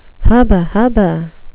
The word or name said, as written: hubba-hubba